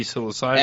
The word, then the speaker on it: psilocybin: How do you pronounce that? Psilocybin magic mushrooms? – Psilocybin, yeah.